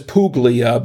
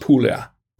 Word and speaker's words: Puglia: It looks like it says Puglia, but it's pronounced Puglia.